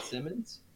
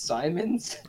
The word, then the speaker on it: Simons: Is it not Simmons? It's Simons. – I'm pretty sure it's Simons. – Simons. – It's spelled Simons. – Anthony Simons. – It's not spelled like Ben Simmons.